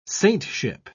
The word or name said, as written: saintship